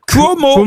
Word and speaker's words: Cuomo: Andrew Cuomo. – Vous, il prononce bien? – Oui, on sait pas. – En plus, il est... – Si ça s'écrit C-U-O-M-O, il prononce bien. – Qu'est-ce qu'on en sait? – Oui, ça s'écrit C-U-O-M-O.